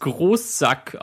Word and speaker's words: Grossag: Ähm, also ich würde es Großsack aussprechen. G-R-O-S-S-A-G. – Großsack.